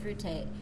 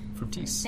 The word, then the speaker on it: Fructis: And I know you used the example of the Garnier Fructis. – Fructis.